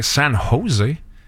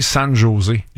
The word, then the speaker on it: San José: Puis un de nous deux, au lieu de dire San José, qui est la prononciation parfaite, un de nous deux aurait dit San José.